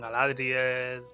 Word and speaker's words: Galadriel: Sobretot, moltes són esdrúxules, no? Com Galadriel, eh, l'home de Galadriel, Celeborn... Bueno, del Saldarín... Si no saps com tirar-li, és drúxula.